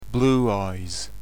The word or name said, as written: blue eyes